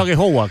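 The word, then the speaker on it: Aghehowa: He dicho Aguejowa, ¿cómo se pronuncia? – Sí, bien, la verdad que... Muy bien, así, muy bien.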